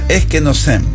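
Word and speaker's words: eskenosem (ἐσκήνωσεν): Mira, cuando aparece Yeshua y la palabra se hizo carne, que lo traducen, y habitó entre nosotros, en Juan 1:14, el verbo griego, se pronuncia eskenosem, que literalmente comunica la idea de levantar una tienda o acampar o tabernaculizar, como te acabo de decir, entre nosotros.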